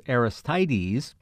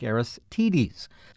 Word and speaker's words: Aristides: Well, I have had a bit of an email dialogue with Clay, who told me the correct pronunciation of what I said was Aristides was more like Aristides, a reference I found online from author Bill Doolittle, who has written about the Kentucky Derby, confirms the long I sound.